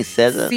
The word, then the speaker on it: SZA: That's how you say her name, SZA.